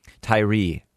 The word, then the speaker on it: Tyre: Tyree is how it's pronounced, but it's spelled Tyre.